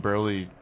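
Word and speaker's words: Buehrle: They also got Mark Burley. Is that how you say his name? Burley. – They got Burley, yeah.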